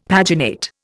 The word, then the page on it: paginate: Indian